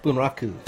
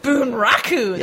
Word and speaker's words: Bunraku: Bunraku? – Bunraku. – Bunraku.